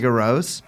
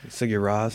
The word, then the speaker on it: Sigur Rós: How do you pronounce it? Sigur Rós. – Sigur Rós.